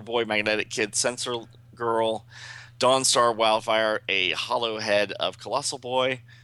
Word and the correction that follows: holo: a holo head, not a hollow head.